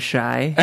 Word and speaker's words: The Chi: Think about how you feel before you say it. You get kind of shy. – Shy. – That's how it's pronounced.